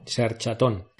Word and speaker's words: Searchatón: Y ya sabéis, con acento en la O. Searchatón.